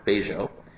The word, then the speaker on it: Bello: In contrast, we have the community of Bejo, even though it's spelled B-E-L-L-O, it's pronounced Bejo.